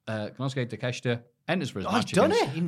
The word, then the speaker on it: Takeshita: Takeshita.